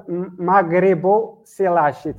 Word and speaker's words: Maghriboselache: It's called Maghriboselache. It's two words together. Maghribo, refers to Morocco. Selache, uh-huh. It's Cloudoselache. It's the species of the shark. So it's two words together. Maghriboselache Mohamezanei.